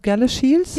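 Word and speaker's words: Galashiels: Und dann hast du deinen Master in Textildesign an der Harriet Watt University in, wie spricht man den Ort aus, Gala Shields? – Ja, genau.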